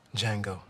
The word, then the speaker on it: Django: Django. The D is silent. – On the next Fresh Air, we talk with Quentin Tarantino about his new movie, Django Unchained.